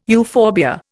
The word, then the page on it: euphorbia: Indian